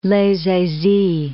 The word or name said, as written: Les Eyzies